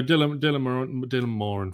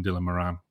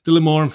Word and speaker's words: Dylan Moran: No, Dylan Moran for me. So that's the correct pronunciation. English people will call him Dylan Moran. But yeah, Dylan Moran for me.